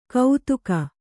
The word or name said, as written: ಕೌತುಕ